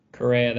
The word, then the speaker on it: Correa: Is it Correa or Correa? – Correa, that was right.